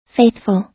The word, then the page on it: faithful: American English